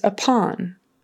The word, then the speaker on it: upon: The next word is upon. Upon. Accent on the second syllable. And make sure that's an ah, ah, short O, upon.